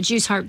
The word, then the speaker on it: Jew's harp: Jew's harp, jaw harp, they're pronounced both the same way.